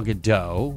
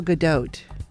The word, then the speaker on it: Gadot: A lot of people say her name's Gal Gadot. I've heard Gal Gadot, which is incorrect. – It's Gal Gadot.